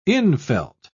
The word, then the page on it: infelt: British English